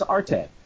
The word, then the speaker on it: arte: He's fucking wrong, because this happens in Italy, and the way you pronounce this word, A-R-T-E, in Italy, is arte. And the way you would say it in katakana is arte.